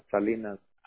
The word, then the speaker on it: Salinas: Mi nombre es Salinas. Así ¿Salinas? – Como Siri Salinas, así como la ciudad, ajá, Salinas.